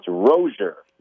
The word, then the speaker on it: Rozier: It's Rozier. – Rozier. – Like closure, which is exactly what we got in this Miami quarterback battle this week.